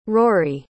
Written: rory

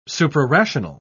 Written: suprarational

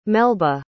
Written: melba

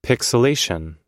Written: pixilation

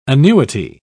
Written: annuity

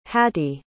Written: hattie

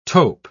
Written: tope

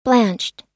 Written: blanched

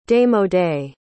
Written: démodé

